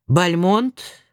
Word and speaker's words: Бальмонт: Бальмонт, прошу читателя, согласно носителю, произносить с ударением на конце.